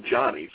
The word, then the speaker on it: Jhonny: But his name has got the H in front of the O. It's spelled J-H-O-N-N-Y, but it's still Johnny.